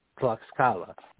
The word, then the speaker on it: Tlaxcala: Tlaxcala. Tlaxcala, just for anybody who's listening, how you spell it is T-L-A-X-C-A-L-A, Tlaxcala.